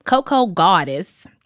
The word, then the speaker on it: Cocogawdes: Coco Goddess. I pronounce it that way because it's spelled COCOGAWDES. – It's definitely goddess.